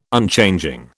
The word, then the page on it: Unchanging: British English